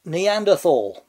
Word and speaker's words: Neanderthal: Should it be pronounced Neanderthal or Neanderthal? The formal scientific name of the species, or subspecies, whatever we think it was this week, is Homo neanderthalensis. Always spelt with a TH, but always pronounced as just T, since Latin doesn't have a th sound.